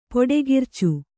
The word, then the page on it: ಪುಡಿಖರ್ಚು: puḍi kharcu